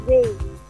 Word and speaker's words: Ray: I think you can call me Ray. – Can you spell it? Spell it for me. – R-A-Y.